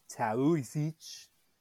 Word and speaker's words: Taoiseach: The spelling is T-A-O-I-S-E-A-C-H. It looks like Taoiseach. It's pronounced Taoiseach.